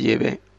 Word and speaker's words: Yahvé: Pues hay eruditos, estudiosos, también, que saben que la pronunciación es Yahvé, es Yahvé.